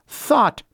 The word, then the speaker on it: thot: It's said to stand for that hoe over there, T-H-O-T, and is pronounced thought.